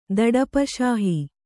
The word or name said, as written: ದಡಪಶಾಹಿ